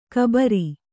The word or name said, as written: ಘಾಬರಿ